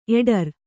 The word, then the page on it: ಎಡರ್: eḍar